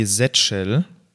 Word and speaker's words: Z-Shell: Ich verwende die Z-Shell. – Oder die so verwende ich, genau, oder? so heißt die ja. ja. Die, die Z auf Deutsch, aus Deutsch ausgesprochen und die verwende ich tatsächlich auch.